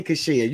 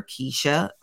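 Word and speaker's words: Keshia: It's Keisha. – I know it's Keisha, but I hear you say Kashia. – It's Keisha, but I have to say Kashia or else I will spell it wrong.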